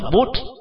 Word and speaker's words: Buddha: Ví dụ như chữ Phật đi, trong tiếng Pali Sanskrit, nếu đọc chuẩn, đọc là Bút, chữ A là một tiếp vị ngữ nằm ở cuối từ, được xem là An Câm.